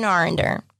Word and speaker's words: Arender: Robin Arender. – And how do you spell that last name? – A-R-E-N-D-E-R.